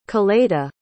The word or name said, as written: Coleta